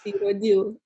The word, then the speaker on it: Irodiu: Well, Rodica Irudeo, did I say that right? – Irudeo.